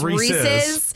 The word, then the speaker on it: Reese's: It's Reese's, which means it's pronounced Reese's.